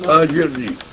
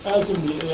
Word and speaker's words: أجرني: كلمة أجرني لوحدها. – أجرني. – أجرني يعني تحول السكون. – إيه نعم. – أجرني. – لأنه السكون لا يبتدا به.